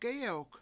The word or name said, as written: gEyewk